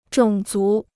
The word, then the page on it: 种族: zhǒng zú